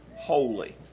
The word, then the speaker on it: wholly: Now he said in 1 Thessalonians 5:23, sanctify you wholly. Now, once again, we keep separated there the two words that we use and pronounce the same, holy, H-O-L-Y or W-H-O-L-L-Y.